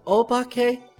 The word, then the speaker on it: obake: O-B-A-K-E. Pronounced Obake.